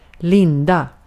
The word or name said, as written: linda